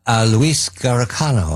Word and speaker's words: Luis Garicano: However, John, you pointed to Chips and Luis Garicano, I hope I say that correctly.